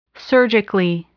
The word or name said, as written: surgically